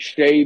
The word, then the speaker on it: Shea: Yes, like Shea Butter. That's what I like to tell everybody. It's spelled the same, everything, and then, yeah, Shea Butter, baby.